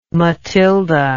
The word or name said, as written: Mathilde